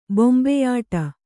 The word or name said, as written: ಬೊಂಬೆಯಾಟ